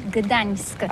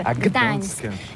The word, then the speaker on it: Gdańsk: Alors d'abord, comment prononce-t-on bien? Ça s'écrit G-D-A-N-S-K. On dit Gdansk ou Gdansk, on prononce le G ou pas? – Oui, c'est pas très facile pour des étrangers, mais remarque bien que la langue polonaise, c'est la langue où on prononce chaque lettre qu'on écrit. C'est pourquoi on dit Gdańsk.